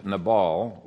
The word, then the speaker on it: Nabal: And from the limited data that we have in the text, it appears that both Nabal, which is the Hebrew pronunciation of Nabal, uh, but both Nabal and uh, Abigail came from pretty good stock.